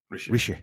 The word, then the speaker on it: Rishi: He's not called Richie. – What's he called? – Rishi. – I got his name wrong.